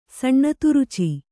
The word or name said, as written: ಸಣ್ಣತುರುಚಿ